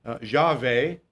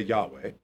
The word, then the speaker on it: Yahweh: Now this is Jehovah, which is a transliteration from Yahweh, which is how they say it in Hebrew. We say Yahweh.